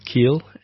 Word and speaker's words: Keel: Surname is Keel. And I pronounced it incorrectly. – It's okay. many people do. – It's like Neil Keel. There you go. Neil Keel.